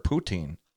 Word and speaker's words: poutine: Well, maybe you could import poutine.